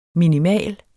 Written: minimal

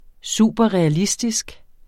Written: superrealistisk

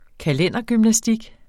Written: kalendergymnastik

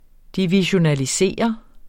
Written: divisionere

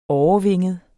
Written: årevinget

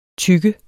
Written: tygge

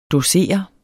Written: dosere

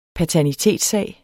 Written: paternitetssag

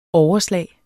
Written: overslag

